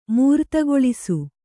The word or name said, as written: ಮೂರುತಗೊಳಿಸು